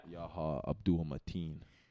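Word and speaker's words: Yahya Abdul-Mateen: Yaha Abdul Mateen. That's how you pronounce from his name.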